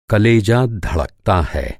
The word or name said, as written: कलेजा